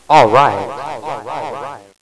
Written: Alright

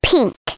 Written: pink

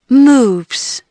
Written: moves

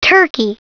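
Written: turkey

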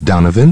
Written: Donovan

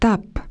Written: tapes

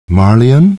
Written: Marlion

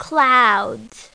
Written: clouds

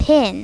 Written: pin

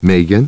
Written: Meghan

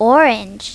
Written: orange

